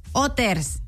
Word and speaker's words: Hooters: Se dice Hooters. – Hoy no más. – ¿No? Bueno, pues sí, ¿dónde? Porque la H es muda.